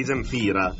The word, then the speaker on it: Zemfira: Allora io la pronuncio così: di Zemifra. – No, di Zemfira.